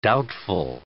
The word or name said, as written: doubtful